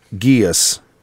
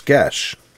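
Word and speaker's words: geis: I'm not sure if I've ever said it out loud, but in my head I've always pronounced it geas. But that rhymes with, says it rhymes with flesh, mesh, and thresh, among other words. So it's gesh. – Is it like geesh? – Gesh. – No, esh, yeah. – Gesh. – Thresh, yeah, you're right.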